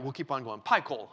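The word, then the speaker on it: Phichol: The actual Hebrew pronunciation of this word is pie coal, like a pie of coal.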